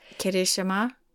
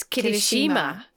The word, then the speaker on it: Kirishima: Well, I listened a lot in the retirement ceremonies to how they were going to say his name. – Kirishima. – It was Kirishima.